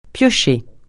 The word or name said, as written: piocher